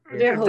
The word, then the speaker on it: Verhoeven: Is that how you pronounce his name? Hoeven? – Verhoeven. Very close enough.